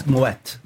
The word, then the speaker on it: Moët: And yeah, if anyone's wondering about the pronunciation, it's because there's a two little dots over the E, which means it's you pronounce both syllables of the word. So it's Moët. Anyway, so I visited them.